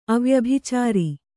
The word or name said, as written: ಅವ್ಯಭಿಚಾರ